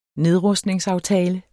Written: nedrustningsaftale

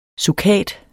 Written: SUKATEN

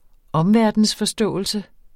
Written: omverden

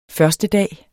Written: førstedag

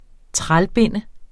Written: trælbinde

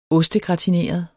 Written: ostegratineret

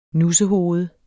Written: nussehoved

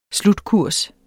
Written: SLUTKURS

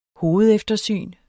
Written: hovedeftersyn

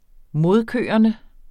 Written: modkørende

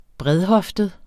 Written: bredhoftet

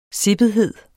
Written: sippethed

